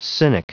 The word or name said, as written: cynic